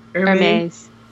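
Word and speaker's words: Hermès: Hermes. The H is silent, it's French. They don't pronounce their Well, you can spend a lot of money.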